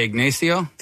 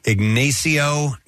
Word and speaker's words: Ignacio: November 6th is National Nachos Day, and apparently it was created by, how do you pronounce the guy's first name? – I would say Ignacio. – Ignacio Nacho Anaya. – Ignacio, not Ignacio, sorry.